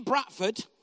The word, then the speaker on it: Bradford: I'm from Bradford originally, spelled B-R-A-T-F-O-R-D. And in Bradford, which is how we pronounce it, we had a cat.